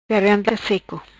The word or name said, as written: Garganta Seco